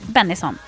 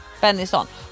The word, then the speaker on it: Bennison: Eh, den släpptes 2007 och Hanna Bennison, du, hur uttalar du det? Bennison? Bennison, ja. Bennison. Hon är då född 2002, fem år gammal var hon när den här låten släpptes.